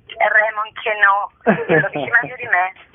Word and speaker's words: Raymond Queneau: Raymond Queneau,